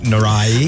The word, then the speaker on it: Norrie: Britain's Cameron Norrie is also part of today's match. – That's actually pronounced Cameron Norrie. – Oh, Norrie. – That's right. Yeah.